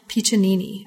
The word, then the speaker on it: Piccinini: And this is an artist called Patricia Piccinini. Am I pronouncing that right? – Yeah, that's right.